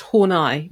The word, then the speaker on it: Horney: And she said, actually, it's pronounced Horney, but there we go.